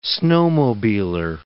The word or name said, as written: snowmobiler